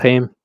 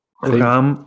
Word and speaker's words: Reims: Reim. – Reim. Yeah, right. It's pronounced, it looks like Reims.